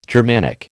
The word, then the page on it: Germanic: American English